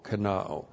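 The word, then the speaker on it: kenao (κενόω): K-E-N-O long O, kenao.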